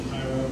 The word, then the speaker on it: Cairo: Cairo, Illinois, which is a ridiculous pronunciation, Cairo, but that's what they call it.